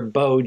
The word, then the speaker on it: beau: But then you get—I'm sure people get confused when they say, "Her, I'd like you to meet Mary and her beau John."